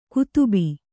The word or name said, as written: ಕದುಬು